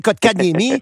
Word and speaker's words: Kotkaniemi: T'es pas obligé de savoir comment l'appeler, mais tu peux le prononcer, Kotkaniemi.